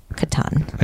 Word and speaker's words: Catan: Betrayal at House on the Hill and Settlers of Catan.